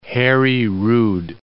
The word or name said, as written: Hari Rud